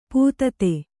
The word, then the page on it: ಪತಿತ: patita